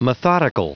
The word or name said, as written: methodical